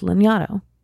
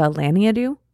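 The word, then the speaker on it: Lanyadoo: And she was on an episode of mine, and the correct way to pronounce her last name is Lignato. But because I'm so literal sometimes with when I see words, I was saying Jessica Lignato.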